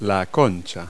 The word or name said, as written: concha